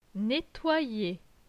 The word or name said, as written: Nettoyer